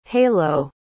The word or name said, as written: hailo